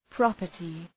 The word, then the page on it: property: ‘prɒpərtı